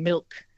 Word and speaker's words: milk: I don't think I pronounce the L in milk. – I have to be articulating it very carefully to say milk.